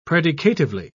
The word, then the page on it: predicatively: British English